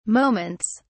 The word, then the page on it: moments: British English